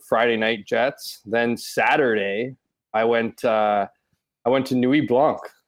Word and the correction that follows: Nuit Blanche: Am I pronouncing that right? – Nuit Blanche, no.